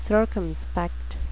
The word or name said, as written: circumspect